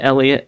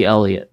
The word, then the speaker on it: Elliot: At Infinite Elliot everywhere. Double E. Infinite double E Elliot. There's two E's there.